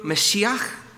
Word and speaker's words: Mashiach: You've got the word Messiah, or as it's pronounced in Hebrew, Mashiach. That's the Hebrew word.